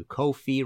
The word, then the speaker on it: Ko-fi: Uh, if you like this episode and want to uh, support future episodes, you can go to Ko-fi rhymes with no fee uh, dot com slash Martin Popoff and hit the red support button.